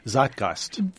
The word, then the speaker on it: Zeitgeist: The Zeit Zeitgeist.